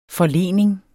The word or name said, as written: forlening